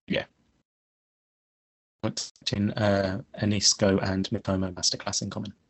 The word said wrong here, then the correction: Enciso: Is it Anisco? – I thought it was Enciso or whatever. I don't know. – Enciso, I can't remember how it's pronounced. – Enciso, I mean, look, we're looking at you for name pronunciations. You were calling Saka Sacco for so long. – And Enciso, Julio Enciso.